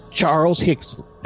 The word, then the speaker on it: Charles Hickson: Charles Hickson. That's H-I-C-K-S-O-N.